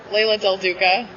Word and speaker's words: Leila Del Duca: You can follow me on Twitter at Leila Del Duca or on Instagram at Leila Del Duck.